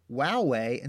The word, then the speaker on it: WOWAY: But there was some, you know, some people who pronounced it Wowway and some people who pronounced it Woway, because you've only ever seen it written. You never heard it because right. This was, you know, an online forum. You know, people were typing, you know, back and forth to each other. So you'd read it in your mind however you read it. So I didn't realize that other people were pronouncing it Woway. I've always just pronounced it Wowway.